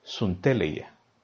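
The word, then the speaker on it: sunteleia (συντέλεια): En, el caso son, en ambos casos son palabras griegas, pero en el caso de la palabra fin, en griego se, pronunciaría sunteleia,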